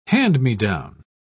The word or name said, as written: hand-me-down